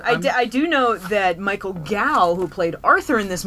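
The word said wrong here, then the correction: Gough: I'm sorry, Michael Goff. I mispronounced that. I'm used to pronouncing him Michael Gow. It's Michael Goff.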